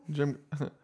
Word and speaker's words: Jim Kyle: People that mispronounce it be like, Jim Kelly? Jim Kelly? – Jim, yeah. Jim Kylie? Jim Kyle? – Jimmy Kylie? – My name is Jim Kyle.